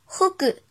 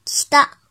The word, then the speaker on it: 北: 音读的时候读作 “hogu hogu hogu”，比如“ 北上 ”“hogu jo hogu jo hogu jo”，写成日语汉字也是“ 北上”。训读的时候读作 “kita kita”。